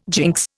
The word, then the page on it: jinx: Indian